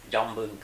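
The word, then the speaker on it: Chombung: I was in a little place called Jombung in Ratbury province. – Can you spell that? Jombung? – Jombung. C-H-O-M. – Oh, okay. – B-U-N-G.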